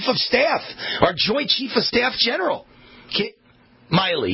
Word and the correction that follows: Milley: yeah, or don't he pronounce it Milley? I think it's pronounced Milley.